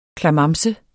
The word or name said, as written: klamamse